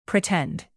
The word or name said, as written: pretend